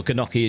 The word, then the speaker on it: gnocchi: Gnocchi. That G is silent, isn't it? It's not gnocchi.